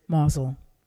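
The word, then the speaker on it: Mousehole: It's spelled mouse hole, but the natives pronounce it Mosel.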